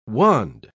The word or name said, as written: wand